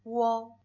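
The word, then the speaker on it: Wo (我): Wo.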